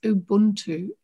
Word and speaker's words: Ubuntu: I've looked up some of the clips online, and my pronunciation of it is Ubuntu, UBUNTU.